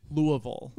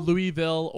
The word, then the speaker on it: Louisville: It Louisville? – It's Louisville. – Louisville. – Yeah. – But they say it's Louisville. – Louisville,